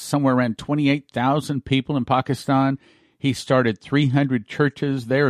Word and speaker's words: Pakistan: He taught me how to pronounce it, Pakistan.